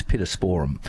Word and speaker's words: pittosporum: And I think with those now, the correct pronunciation, we were corrected by an visiting English lecturer when I was at Horticultural College many years ago. It's a Latin word, so therefore it has no emphasis. It's pittosporum, but we in Australia say pittosporum.